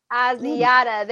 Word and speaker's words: Asiata: But either way, when he was gone, he was able to get Jarek McKinnon and Matt Asiata. – Asiata, there we go.